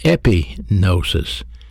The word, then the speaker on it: epignosis: In this instance, he uses another word called epignosis, E-P-I-G-N-O-S-I-S. These are two different words. Epignosis. Epi means full, not partial. Information heard and understood. The word epignosis, information heard and understood and applied.